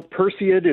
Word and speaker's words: Perseid: But first, how do I say this? – You know, Perseid is the way that I say it, but it's one of those things. There's lots of variation.